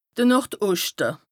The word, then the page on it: de Nord-Oschte: Bas-Rhin; Strasbourg